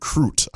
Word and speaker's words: Crute: So the details on this young man, his name is Jimmy the Brute Crute. I hope I pronounced his last name right, because it rhymes with brute.